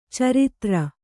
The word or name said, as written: ಚರಿತ್ರೆ